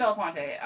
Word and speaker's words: Belafonte: I think it is Belafonte. It's definitely Belafonte.